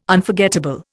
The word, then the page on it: Unforgettable: Indian